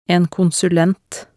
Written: en konsulent

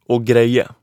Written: å greie

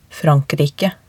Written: Frankrike

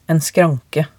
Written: en skranke

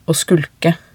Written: å skulke

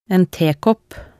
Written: en tekopp

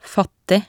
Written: fattig

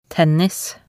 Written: tennis

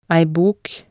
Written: ei bok

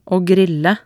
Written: å grille

